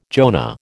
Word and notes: Jonah: American English

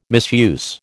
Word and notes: misuse: American English

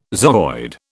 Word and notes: zooid: British English

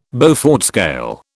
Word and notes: Beaufort scale: British English

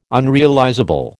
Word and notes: unrealizable: American English

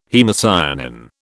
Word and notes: haemocyanin: British English

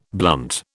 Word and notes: blunt: British English

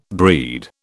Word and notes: breed: British English

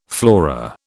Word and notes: flora: British English

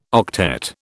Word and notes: octet: British English

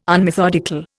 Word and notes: unmethodical: Indian

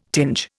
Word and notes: tinge: Indian

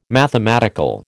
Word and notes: mathematical: American English

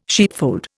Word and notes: sheepfold: Indian

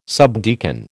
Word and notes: subdeacon: American English

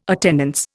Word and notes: attendance: Indian

